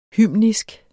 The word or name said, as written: hymnisk